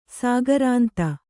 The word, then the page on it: ಸಾಗರಾಂತ: sāgarānta